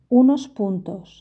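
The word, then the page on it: unos puntos: Spanish (Spain)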